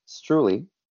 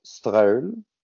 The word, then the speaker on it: Streule: So this one in English, we call it, we pronounce it Streule. In French, my dad and mom will go Streule. But in Swiss German, it's pronounced Streile.